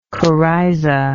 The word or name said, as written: coryzal